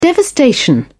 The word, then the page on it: devastation: British English